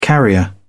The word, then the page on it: carrier: British English